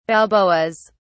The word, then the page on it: balboas: British English